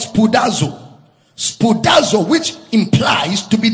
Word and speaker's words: spoudazo: It is imperative to note that Brother Paul used the same word spoudazo. If you are making notes, spoudazo is spelled as S P O U D A Z O.